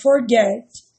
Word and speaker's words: forget: Mas, por exemplo, o verbo forget termina em CVC, T, E, G. E a sílaba tônica é o get. Forget.